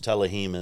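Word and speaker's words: Tehillim: Proverbs, Telehim is how you say Psalms in Hebrew, yes.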